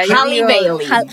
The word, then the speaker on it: Halle Bailey: Halle Bailey.